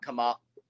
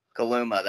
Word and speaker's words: Kaluma: K-State basketball, better without Kamala? Is that how you say, pronounce his name, or is it Kuma? – Kaluma. – Kaluma, that's right.